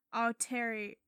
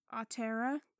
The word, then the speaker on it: Aotearoa: Tonga, Tahiti, Samoa, Fiji, Aotearoa. I know that's not how it's pronounced. – Aotearoa.